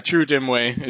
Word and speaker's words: Chirrut Imwe: How to pronounce this. Chirrut Imwe? – Yeah, Chirrut Imwe is the character, the blind character.